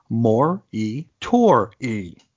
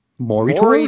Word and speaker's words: Moratori: To which the editor responds, "Just for the record, it's pronounced Mor-E-Tor-E." – Bingo. – Mor-E-I-H-T-O-O-R-E-E. Moratori. – Moratori.